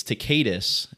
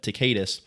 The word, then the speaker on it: Tacitus: It's Tacitus is how I pronounce it. Some people pronounce it differently, but Tacitus, he connected Jesus Christ when he was, I mean, sentenced to hang on a cross from Pontius Pilate.